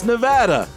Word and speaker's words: Nevada: Certainly not in Nevada, because apparently I can't pronounce the state properly. – It's Nevada.